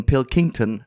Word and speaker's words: Pilkington: Die sagt Pilkington oder dann, dann er, sie haben es gerade dreimal verschieden und dreimal falsch gesagt. Also er, er, geht auf das King, Pilkington.